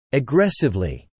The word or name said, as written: aggressively